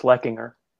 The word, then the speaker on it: Leckinger: Yeah, it's very close, Leckinger.